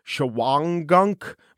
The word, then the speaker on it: Shawangunk: It's spelled Shawangunk, but it's pronounced Shawangunk by the locals.